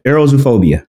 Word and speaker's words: aerozoophobia: Aerozoophobia. – Okay. – Oh, okay. – Yeah. – Arrow, air, zoo, animal, phobia, fear of.